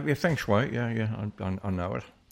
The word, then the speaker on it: feng shui: Feng shui, I believe it's pronounced.